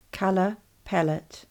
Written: Colour pallette

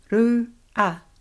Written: rua

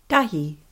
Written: tahi

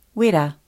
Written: wera

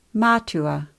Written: matua

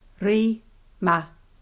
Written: rima

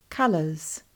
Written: Colours

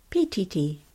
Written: Pititi

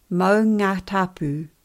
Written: maungatapu

